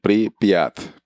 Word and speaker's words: Pripyat: voy a pronunciarla bien, Pripyat, Pripyat, una ciudad de Ucrania.